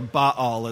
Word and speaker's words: Baal: It's the word Baal, or Baal, as some people pronounce it.